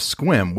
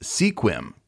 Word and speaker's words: Sequim: It's near the town of Squim, which, from the way it's spelled, looks like it should be pronounced Sequim, but it's Squim.